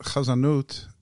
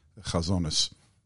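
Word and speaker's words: chazanut (חזנות): In Hebrew, you would just say chazanut. – You know what? Chazanut is a good term, although I prefer its European or Ashkenazic pronunciation, chazonis. It has a certain connotation. Especially since the area of cantorial music that I know best is the Central and Eastern European cantorial tradition. So, chazonis. – So how does one in the 20, are we in the 21st century?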